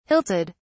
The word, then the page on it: hilted: British English